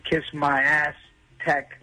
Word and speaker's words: Kiss My Aztec: And now they're giving you a chance with Kiss My Ass Tech. That's how you say it, by the way.